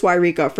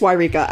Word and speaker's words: Yreka: but I know that it's Yreka. For those of you listening who are still laughing at Lohea Lajala, I promise you, this is Lajala. How you say it, and it's Yreka.